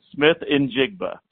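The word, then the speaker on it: Smith-Njigba: And he did confirm it is Jackson Smith Njigba. So that is the correct pronunciation of his last name. He said Smith Njigba.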